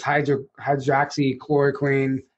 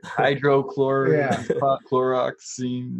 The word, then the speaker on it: hydroxychloroquine: Hey, you said it right. – Yeah. – Yeah. – It's so you said it better than most of the people on stage up there. – I know. Most people are like hydrochloroxine.